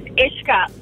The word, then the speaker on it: Uische: My cousin's name is Ishka, and it's spelled U-I-S-C-H-E. – U-I-S-C-H-E.